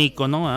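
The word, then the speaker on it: ícono: puso un icono, o un icono, ah, para los que lo pronuncian con el acento equivocado.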